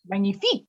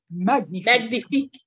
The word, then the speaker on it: magnifique: I think she said magnifique instead of magnifique.